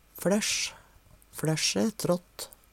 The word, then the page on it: fLæssj: Numedalsmål